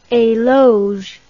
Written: eloge

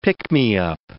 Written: pick-me-up

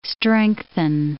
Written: strengthen

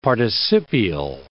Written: participial

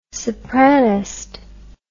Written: sopranist

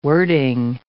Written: wording

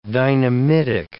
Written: dynamitic

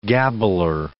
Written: gabbler

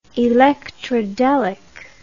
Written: electrodelic

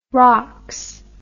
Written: rocks

